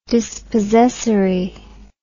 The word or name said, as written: dispossessory